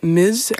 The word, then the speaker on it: Ms: Maar wat je ook vaak op ziet komen is het overkoepelende mis. En dat spreek je dan uit met een z, maar je schrijft het als ms.